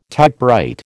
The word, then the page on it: typewrite: American English